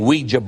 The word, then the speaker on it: oui: Have you ever thought about the name Ouija board? And it's not Ouija, it's Ouija board. O-I-J-A.